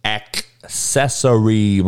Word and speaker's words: accessory: Do not say it with S's pronunciation. It is with C's. Accessory, motherfucker. You motherfuckers are saying accessory. It ain't that word. It's accessory. Act like it's a K in there instead of a C.